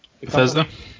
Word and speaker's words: Bethesda: how do you guys pronounce the Bethesda? – Yeah, which is also owned by Microsoft.